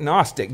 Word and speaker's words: Gnostic: You just don't pronounce the G. But agnostic, Gnostic.